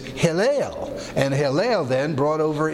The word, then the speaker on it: Helel: That comes from Hillel, and so his name H-E-L-E-L, Hillel, long E pronounced A in from the Greek, is his actual name.